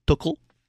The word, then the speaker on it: Tuchel: Now I'm going to try it. Tuchel? – Yes. – Did I say it right? – I think so.